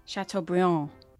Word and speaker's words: Chateaubriand: I want to give it to him really badly, but in the French, you don't pronounce the last letter of the word. Chateaubriand.